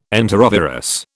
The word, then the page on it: enterovirus: British English